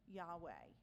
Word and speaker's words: Yahweh: So all this is wrapped up in the letters YHWH, which we pronounce Yahweh.